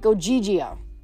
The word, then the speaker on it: Ogygia: You're in Ogygia." She pronounced it like Ogygia.